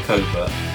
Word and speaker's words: covert: Anywhere a fox may live is called covert, and that is pronounced with a silent T. So it is covert. The hounds will be entered into the covert, often in complete silence from the hunt.